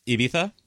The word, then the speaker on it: Ibiza: I really liked your pronunciation on the Z on Ibiza. – Ibiza? – Yeah, very good. – That's how you do it.